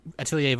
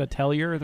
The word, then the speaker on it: Atelier: But the R is silent. What is it? What is it, French or something? Come on. – Yeah, not my fault. In Nox's name, the K is loud. So it's no Atelier is spelled with an R at the end. It's confusing.